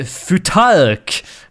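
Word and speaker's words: Futhark: Eh, som ju eh, man brukar kalla för ett visst namn och du frågade mig hur man uttalar, för det, uttalar det och jag sa att det var eh, Futark.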